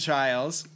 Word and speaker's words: Chiles: And Jordan Chiles, which rhymes with Biles, are both almost locks to be the Olympic team.